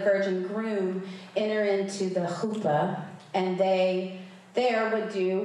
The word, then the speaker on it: chuppah: And they would exchange their covenant vows before God and family, and then they would enter into what was called the chuppah. Did I say that right? You have to kind of get a cat, like, you know, hairball kind of thing going on.